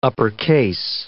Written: uppercase

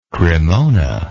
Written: cremona

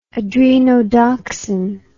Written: adrenodoxin

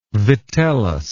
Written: vitellus

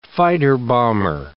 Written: fighter-bomber

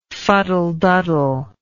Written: fuddleduddle

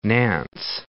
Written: Nantes

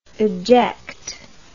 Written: adject

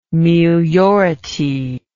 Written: meliority

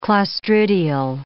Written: clostridial

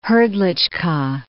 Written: Hrdlicka

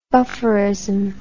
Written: bufferism

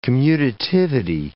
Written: commutativity

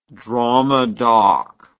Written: dramadoc

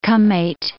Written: Kamet

